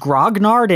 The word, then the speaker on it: grognard: So wait, is it pronounced grognard in English? – In English, yes.